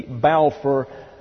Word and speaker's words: Balfour: Prominent in the Zionist movement, he therefore became successful in obtaining the Balfour Declaration. That's B-A-L-F-O-U-R, pronounced Balfour.